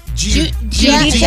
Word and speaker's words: Giudice: And I know I'm going to say this wrong, but it's Teresa Giudice. – Yeah. – Giudice, yeah. Maybe? – Yeah, Diamond says, yeah, that's fine.